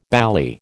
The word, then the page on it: bally: American English